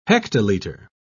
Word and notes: hectolitre: British English

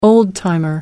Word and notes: old timer: American English

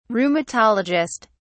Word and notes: rheumatologist: British English

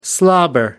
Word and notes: slobber: American English

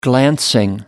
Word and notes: glancing: American English

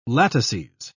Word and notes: latices: British English

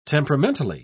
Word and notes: temperamentally: British English

